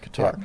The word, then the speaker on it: Qatar: You're right, Qatar.